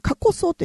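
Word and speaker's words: 夏枯草: 夏枯れ草っていうのは、過去草って夏枯れ草、夏に枯れる草と書いて過去草というふうなことですね。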